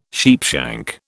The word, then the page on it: sheepshank: British English